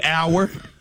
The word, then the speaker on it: hour: This was another one that threw me hour. – Like hour. – Like time. – Yeah. The H is silent. – Exactly. H-O-U-R, the H is silent.